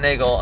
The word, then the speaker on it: Neagle: Uh, and uh, social is just Neagle, just dot Neagle, and that's N E A G L E, spelled like beagle, pronounced like bagel.